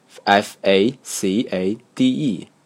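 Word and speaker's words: façade: 印刷题里写的不是 facade，而是 f a c a d e，英文里没有 sa 这个字母，带尾巴的 c，于是我就想当然的把它读成 facade，还觉得自己读得很标准。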